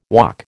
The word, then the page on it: Waac: American English